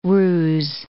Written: roose

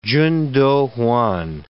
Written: Chun Doo-Hwan

